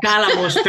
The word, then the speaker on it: Cálamos: También organizas y has presentado eventos como el poético musical Calomos y Péndolos. No, lo digo bien, perdón. – Cálamos y Péndola. – Cálamos, perdón, Cálamos, el acento.